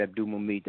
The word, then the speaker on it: Abdul-Mumit: So it'll be Jihad Abdul-Mumit.